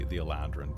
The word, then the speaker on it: Eladrin: Eladrin is the way it's spelled in the book. It's more fanciful in Elvish, but that's the common spelling. Most people in common would call it the Aladrin, but the Aladrin is how it's pronounced in Elven, which, of course, everything in Elven sounds like you're just trying to squeeze a little more cursive script into it.